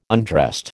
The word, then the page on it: undressed: American English